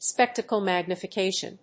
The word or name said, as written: spectacle magnification